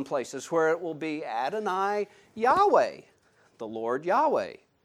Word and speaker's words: Adonai: Sorry for that. Messed it up. Known it for years. Adonai. Yeah, they haven't changed the pronunciation.